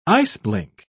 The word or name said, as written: iceblink